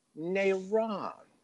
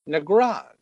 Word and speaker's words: Negron: In most of the world, that G is silent. Nairon. It sounds very classy to me. Mm. But she probably says Charlene Mead Negron.